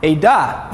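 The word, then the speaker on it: Eda (עדה): Eda.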